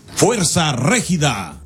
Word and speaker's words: Fuerza Regida: Fuerza Regida.